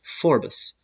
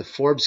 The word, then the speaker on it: Forbes: And in this case, I'm going to use the example of the Forbes, which I believe at one time in history was pronounced Forbes, as in two syllables.